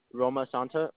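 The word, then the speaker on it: Romasanta: Roma Santa, R-O, M as in Mary, A-S-A, N as in Nate, T-A.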